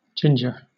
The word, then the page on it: ginger: British English